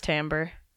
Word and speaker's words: timbre: It's pronounced timbre. – What? – It's spelled with an I, but it's pronounced timbre. I promise.